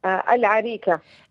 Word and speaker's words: العريكة: أوكي، هذه الوصفة كيف تنطق بالضبط؟ العريكة ولا الأريكة؟ – العريكة.